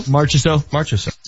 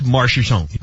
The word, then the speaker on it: Marchessault: Marchessault. – So they would select Marchessault.